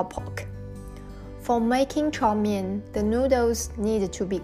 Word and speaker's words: 炒面: The 炒面 is the Cantonese pronunciation of the Chinese characters above, which means stir-fried noodles.